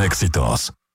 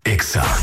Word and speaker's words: EXA: No se dicen éxitos. Se dice EXA.